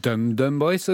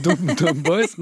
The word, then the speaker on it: DumDum Boys: Ja, for det første så heter det DumDum Boys og ikke DumDum Boys, men ok.